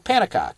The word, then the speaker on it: Pannekoek: Panikok. Yep. – I've read it. P-A-N-N-E-K-O-E-K. Panikok. – Panikok. – Panikok. – I don't know. – So then we're going to transition to section three. Kautsky's controversy with Panikok.